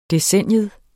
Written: DECENNIET

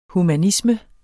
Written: humanisme

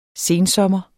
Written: sensommer

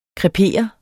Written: krepere

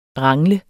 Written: rangle

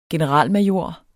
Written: generalmajor

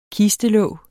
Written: kistelåg

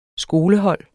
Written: skolehold

